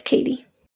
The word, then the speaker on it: Kétie: I thought her name was like Kétie. – Her name's Kétie, and I'm getting there. – Okay. This spelled like K-E-T-I-E, though. – Yeah, but it's Kétie.